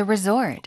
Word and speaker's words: resort: Many restaurants are concentrated around the resort. – One more time. – Many restaurants are concentrated around the resort. – 리조트, 발음이요. Resort, 뒤에 강세입니다.